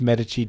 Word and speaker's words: Medici: I know it's pronounced Medici. Don't write in.